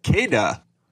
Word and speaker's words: Kada: It's KAEDAAT 11. Pronounced Kada. – Cool. – So I'm sure you can find spell that one more time. – That. – Spell that one more time. – KAEDAAT 11. – Okay, it's Kada, but with two A's.